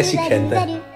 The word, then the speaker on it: Sikandar: Mit navn er Sikandar, og det rimer på panda.